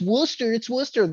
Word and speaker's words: Worcester: So explain fucking Worcester. Explain that to me. All these fucking Boston people. It's Worcester. Where's the fucking R? Where's the R?